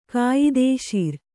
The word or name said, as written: ಕಾಯದೇಶೀರ